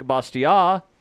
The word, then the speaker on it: Bastiat: Others, such as Frederick Bastiat, I'm guessing that's the correct pronunciation because he was French, so I don't think the T is supposed to be pronounced.